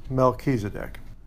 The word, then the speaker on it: Melchizedek: The name is pronounced Melchizedek.